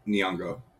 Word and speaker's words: Nyong'o: Anyway, a 1-minute teaser ad was aired during the NBA Finals on June 7th, featuring actors Mahershala Ali, Lupita Nyong'o. Admittedly, I don't know how to pronounce it. Nyong'o? – It's Nyong'o. – Who? – Nyong'o. – Nyong'o. – Yeah, like Miyagi.